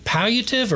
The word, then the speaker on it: palliative: So hospice and palliative medicine is combined when spoken about. – Is it palliative or palliative? – Palliative. P-A-L-L-I-A-T-I-V-E. Palliative. – We'll go ahead and go with your pronunciation.